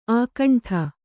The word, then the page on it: ಅಖಂಡ: Kannada